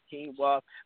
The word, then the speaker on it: quinoa: It's uh, it's quinoa sauce with a Q, but it sounds like it's it could be with a K, but it's pronounced quinoa.